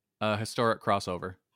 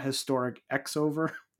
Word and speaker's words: Historic Crossover: I also realized when I was writing out our notes for the show, how do you pronounce the name of this show? – Historic Crossover. – Okay. Because I've only ever seen it with the X, and I've never heard anyone say the name of the event out loud. So I'm like, it is Historic Crossover, right? Not Historic X-over.